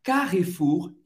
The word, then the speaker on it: Carrefour: Você vai ter que pronunciar esse R bem francês, bem, euh, gutural, ok? Carrefour. Você não vai pronunciar o E no meio, Carrefour. Então, nossa primeira, primeira marca, Carrefour. E você vai pronunciar também o R no final, Carrefour. Segunda marca, Casino.